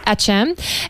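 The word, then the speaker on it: Akçam: And I mean, one of the latest developments that we've seen from one of the foremost scholars in this area is Tana Assam. Is that how to pronounce his name? – Acham.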